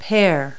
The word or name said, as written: pear